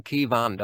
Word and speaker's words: Kayvan: Aber wie gesagt, äh, Kai, wenn man das K-A-Y liest, das ist ja aus dem Farsi übersetzt, dann wird man natürlich immer gerne Kai sagen, aber es ist einfach Ke. Äh, man kann sich das auch phonetisch merken, K-E-W-A-N, Kewan, dann kann man es aussprechen. Aber okay, passt auch. Viele nennen mich dann auch Kaiwan, aber Kewan ist halt richtig.